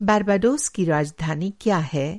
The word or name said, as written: बारबाडोस